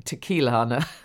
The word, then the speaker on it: Tequilana: And apparently its full name is Agave Ticalana. I wouldn't have said that right, will I? Tequilana Blue Weber.